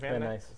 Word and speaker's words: Van Nuys: Van Nuys. – Thank you, California. And that's in the U.S., of course. – Unless it's Venice. – V-A-N-N-U-Y-S. – Oh, Van Nuys. – Yeah, okay. – Van Nuys.